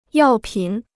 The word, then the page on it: 药品: yào pǐn